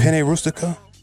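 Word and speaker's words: penne rustica: Am I saying that right? Penny rustica? – Yeah.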